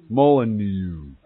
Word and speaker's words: Molyneux: It's a Stephen, how do you say that guy's name? Moulinex? Moulinex. It wouldn't be X. He's French or something. He's a bald guy. He does videos. Molyneux.